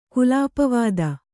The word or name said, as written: ಕುಲಾಪವಾದ